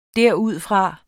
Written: derudfra

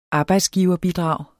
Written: arbejdsgiverbidrag

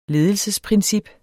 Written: ledelsesprincip

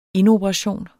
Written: indoperation

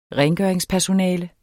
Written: rengøringspersonale

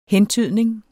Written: hentydning

